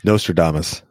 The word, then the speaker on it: Nostradamus: Maybe it's another topic for another day, but yeah, I claim to be, I could be Nostradamus. Is that how you say it? – Nostradamus.